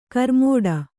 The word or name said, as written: ಕರ್ಮಠ